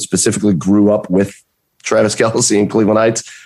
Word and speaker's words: Kelce: It's Kelce now.